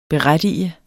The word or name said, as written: BERETTIGER